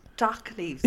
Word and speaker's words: dock leaves: Why are you calling them doggy leaves? – Were they not called doggy leaves? – Dock leaves.